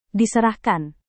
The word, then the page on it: diserahkan: indonesian